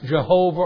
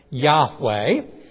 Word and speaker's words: Yahweh: It could be pronounced Jehovah, or probably more properly, Yahweh.